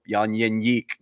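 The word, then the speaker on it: Jan Jeník: Yeah, S-H-L. Like when people say Jan Jenik, I hear it on the air all the time, and I keep hearing his voice in my head saying, nope, Jan Jenik. That's actually how it's pronounced.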